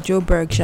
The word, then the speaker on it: Joburg: Joburg, yeah.